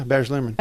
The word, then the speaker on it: Baz Luhrmann: Yeah, Baz Luhrmann.